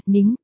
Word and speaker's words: Ninh: Sao, Ninh là Ninh, tên của Diệp Ninh, phát âm là, nính, còn Linh nghĩa là số không kia thì là Linh, phát âm là, lính. Phát âm khác nhau mỗi L với N thôi mặt cười, Đại Mỹ Ninh nghĩa là Ninh Đại Mỹ Nhân.